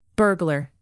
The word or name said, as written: burglar